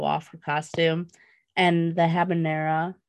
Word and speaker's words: habanera: So the costume, she was humming the habanera. Is that how you say it? – Habanera.